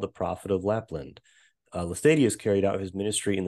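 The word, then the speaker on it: Laestadius: There's an awakening movement there, you said, that was led by Lars Levi Lestadius. Am I pronouncing that right? – Lars Levi Lestadius, yeah.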